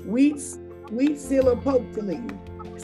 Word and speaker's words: Huitzilopochtli: And so we've gotten all the way to it is, how do we pronounce it, Huizilopochtli.